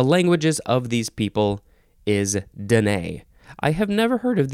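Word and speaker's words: Dené: The next word is denay or denay, but the emphasis is still on the second syllable. This is spelled capital D-E-N-E, and both E's have the accent that goes boop, denay.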